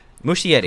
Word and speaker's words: Muschietti: Muschietti. – Name? Muschietti.